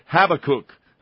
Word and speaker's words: Habakkuk: Have a cake. How have can you cake. Miss that? No, that's not right either. Thanks a lot. It's like apocalyptic. You know, my wife's going to fool me again. Habakkuk. I think is the correct pronunciation.